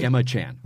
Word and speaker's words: Gemma: Is it Gemma? Is it a hard G? – Gemma, I think. Gemma, oh, really? – Gemma Chan. – Oh. – I believe so.